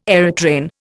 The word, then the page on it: aerotrain: Indian